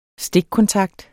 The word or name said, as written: stikkontakt